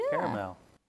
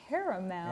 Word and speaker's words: caramel: If I pronounce every vowel, I understand that it's spelled caramel. Mm-hmm, mm-hmm. But that's just when it comes out. It's just caramel. – There you go. – I just say caramel. How about P-E-C-A-N?